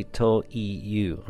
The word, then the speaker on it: という: 忠直教行情記という小説忠直教行情記という小説 Okay, so you might have noticed that when Reiko says toyu, she does not pronounce the e in the middle of that. So it's actually toyu, right? But she just says toyu. So coincidentally, she cannot hear this difference, but I picked up on it. so when you pronounce it, you don't have to say that e. It's toyu.